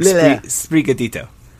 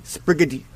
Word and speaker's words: Sprigatito: Sprigatito. – Okay. Sprigatito. Sprigatito. – Yeah, it's like Sprite. – Gatito. – Sprigatito. There you go. Sprigatito.